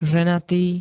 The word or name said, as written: ženatý